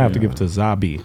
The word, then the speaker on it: Zabbi: Zaba. Like Zaba. Rabbi Zaba. – Say spell it. – Z A B B I. – Zabi. – Zabi.